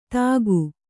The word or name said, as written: ಥಾಕು